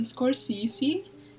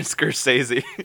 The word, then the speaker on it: Scorsese: It was directed by Martin Scorsese, who's really big. I don't think I pronounced his last name correctly. – I think it's Martin Scorsese.